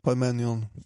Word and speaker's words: Parmenion: Hans närmsta general till exempel Parmion Satte jag uttalet? – Ja, det är något åt det hållet. Parmenion.